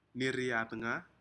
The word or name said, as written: niri atga